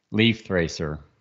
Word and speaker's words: Lifthrasir: Lifthrasir.